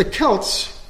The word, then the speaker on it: Celt: The Celts, by the way, Don't pronounce it Celts when you're out there in Scotland or Ireland. I'll correct you, Celt. The C is pronounced K, Celt. They're not Celtic, they're Celtic. And I've had people rebuke me very severely when I've mispronounced it over there.